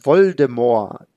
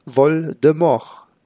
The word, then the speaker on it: Voldemort: Lord Voldemort. Kaum bekannt ist, dass das T am Ende eben nicht ausgesprochen werden soll.